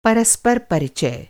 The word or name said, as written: परिचय